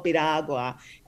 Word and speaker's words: Piragua: Cause it's we're going to say that he knows the product well enough, the way he described it, and came close enough to the pronunciation of the word that, well, you heard the fanfare. We gave it to him. You want to say it right? – Lin Miranda was selling them in the film On the Heights. – In the Heights, yeah. So it's called Piragua